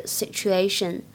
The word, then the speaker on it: situation: 那可真是两败俱伤的局面啊！在整句话的朗读过程当中呢，我们注意一下 would。和 have 当中有不完全失去爆破的现象。其次，注意一下 lose 当中字母 s 的发音呢，不要发成 s。最后呢，讲一下这个单词 situation 它的发音当中的爆破音 t 跟后面的半元音也放到一起之后呢，读起来会有一点音的同化的现象，有点像 ch 这个音，所以读起来是 situation situation。